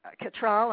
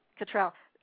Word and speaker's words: Cattrall: But I'm like you, I liked all of the performances, but the one who wowed me the most was Kim Cattrall. Am I pronouncing that right? – Uh-huh. – Cattrall.